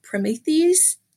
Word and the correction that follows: Promethease: Oh, yes. Promethease. Yeah. Thank you.